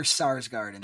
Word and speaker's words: Sarsgaard: Skarsgård. Pronounce it. – It's Peter Sarsgaard.